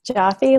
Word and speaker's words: Jaffe: Jaffe, like coffee.